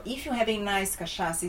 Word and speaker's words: cachaça: Cachaça or gin and tonic? – I don't know.